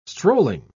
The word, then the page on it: strolling: British English